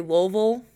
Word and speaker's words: Louisville: But they say Louisville. – Like, I don't think you pronounce the S. It's like Louisville.